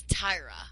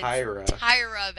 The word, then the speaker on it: Tyra: It's Tyra, okay? If she's gonna be your pick, say it right. I'm sorry to get all grammatical, but it's Tyra Banks.